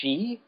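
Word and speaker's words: she (四): So I guess the pronunciation of four in Japan is she.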